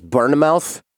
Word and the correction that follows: Bournemouth: Bournemouth? Oh, Bournemouth. Bournemouth.